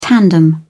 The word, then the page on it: tandem: British English